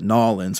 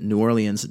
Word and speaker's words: New Orleans: Your own fucking mayor pronounces it New Orleans just like I do.